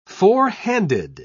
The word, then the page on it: fourhanded: British English